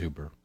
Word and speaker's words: Uber: He says, Dad, that's not how it's pronounced. It's, how do you say it? Uber? – It's Uber.